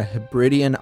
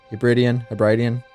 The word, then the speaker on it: Hebridean: It's a thriller that takes place primarily on a Hebridean island over by Scotland. I think I'm pronouncing that right. Hebridean? Nah, I think it's Hebridean.